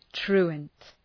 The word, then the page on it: truant: ‘tru:ənt